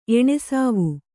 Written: ಎಣೆಸಾವು

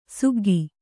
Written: ಸಿಗ್ಗು

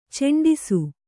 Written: ಚೆಂಡಿಸು

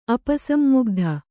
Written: ಅಪಸನ್ಮುಗ್ಧ

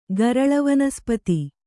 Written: ಗರಳವನಸ್ಪತಿ

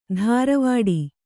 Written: ಧಾರವಾಡಿ

